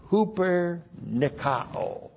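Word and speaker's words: hupernikao (ὑπερνικάω): The key verb is hupernikao. Hupernikao. Looks like this in the Greek. H-U-P-E-R. That's a preposition. Huper. And then Nikao, N-I-K-A, long O. Huper Nikao. That's the verb.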